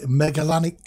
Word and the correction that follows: Magellanic: No, it's Magellan, isn't it? It's a Magellanic. – Yes, Magellanic.